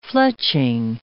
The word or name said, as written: fletching